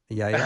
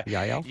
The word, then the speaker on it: Jael: I'll continue to work on saying that. Yael. – I know. – Yael. – You get that Hebrew pronunciation down, because I've got nothing. That J confuses me right up front. – I know. – And so, and it's apparently a silent J, but that was the case with Dr. Mark Muska too, right? – Yehoiada, you still have that one down.